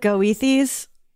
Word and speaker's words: Goethe: And I cackle because the spelling, Goethes or whatever. It looks like Goethes. – Oh, right. – Okay, sure. – But it's pronounced Goethe,